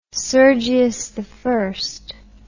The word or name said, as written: sergius i